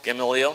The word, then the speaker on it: Gamaliel: Is it Gamaliel or Gamaliel? – Gamaliel. – Gamaliel? – I would say, yeah. That's what you would say? Then whatever he says is what I'm going with. Gamaliel, who was the grandson of the famous rabbi Hillel.